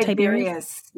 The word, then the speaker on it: Tiberius: And I know, is it Tiberius? Is that how you say it? – Tiberius, yes.